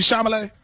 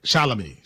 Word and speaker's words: Chalamet: it Timothy Chalamet? – He said Charlamagne.